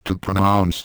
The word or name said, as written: to pronounce